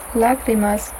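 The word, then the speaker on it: Lágrimas: Lagrimas? – Lagrimas, Tränen.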